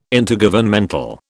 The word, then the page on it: Intergovernmental: British English